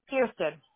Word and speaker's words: Kirsten: Is it Kristen or Kirsten? – Kirsten.